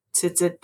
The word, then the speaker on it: tzitzit: And your example is Jesus's cloak is really his tzitzit. Did I say that correctly? – Tzitzit. – Tzitzit.